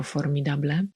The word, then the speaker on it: formidable: Znowu wypowiada się tutaj Snape i on używa tutaj przymiotnika formidable. Musiałam sprawdzić, jak to się wymawia, bo nie byłam pewna akcentu. Akcent jest na drugą sylabę. Zapisujemy to słowo jako formidable i ono znaczy ogromny albo godny podziwu.